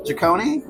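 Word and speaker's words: Jikoni: You know, Jaconi, am I saying that right? The Indian restaurant in Marylebone? – Yes.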